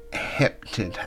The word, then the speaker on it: heptad: This group of seven here is considered to be another heptad, I believe is how you say the word. and it's spelled H-E-P-T-A-D, so Heptad, Heptad.